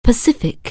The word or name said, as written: Pacific